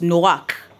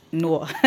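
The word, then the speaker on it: Noach (נֹחַ): It's pronounced Noach, not Noah. Noach, you know, and Noach means rest.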